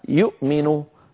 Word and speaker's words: يؤمن: يؤمن أصلها يؤمن، نعم، آمن يؤمن، فإذا أبدلنا همزتها واوا لأنها ساكنة يصير النطق يؤمن، وهذا أمر تفعله العرب، بل تفعله قريش نفسها.